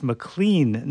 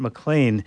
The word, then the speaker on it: McLean: So Jackie McLean, I was corrected on this. It's pronounced McLean, not McLean, is certainly going to get featured today on this edition of In Transition.